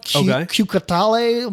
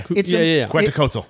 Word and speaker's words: Quetzalcoatl: The Mexican like dragon death god, Q-Catale, whatever the fuck it is. – Okay. – Quetzalcoatl.